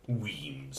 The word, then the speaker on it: Wemyss: If you feel moved to toast them, remember it's pronounced Wemyss.